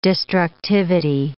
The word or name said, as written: destructivity